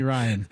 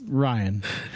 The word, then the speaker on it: Ryne: I said, my parents call me Ryan. It's spelled Ryne, but it's Ryan.